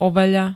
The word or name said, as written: overený